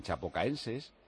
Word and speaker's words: Chapecoense: Alineación del Palmeiras, que si empata con el Chapocoense, ¿cómo es? ¿Cómo se llama? A ver si lo digo bien. Chapecoense.